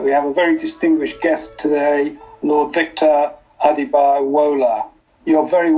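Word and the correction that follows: Adebowale: It's a good attempt. It's good old Yorkshire name. It's pronounced Adibawali. – Adibawali.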